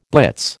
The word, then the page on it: blitz: American English